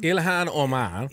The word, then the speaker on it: Ilhan Omar: Ilhan Omar.